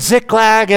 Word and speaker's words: Ziklag: So David was using Ziklag as an outpost.